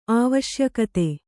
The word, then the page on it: ಆವಶ್ಯಕತೆ: āvaśyakate